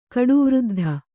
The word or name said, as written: ಕಡುವೃದ್ಧ